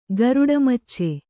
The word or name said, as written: ಗರುಡಮಚ್ಚೆ